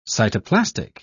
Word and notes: cytoplastic: English; British English

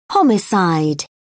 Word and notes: homicide: British English

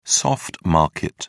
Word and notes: soft market: British English

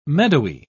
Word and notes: meadowy: British English

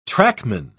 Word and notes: trackman: British English

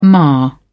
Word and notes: ma: British English

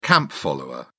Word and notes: camp follower: British English

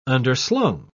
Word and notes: underslung: British English

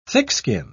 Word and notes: thickskin: British English

